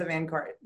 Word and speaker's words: VanCort: Eliza VanCort.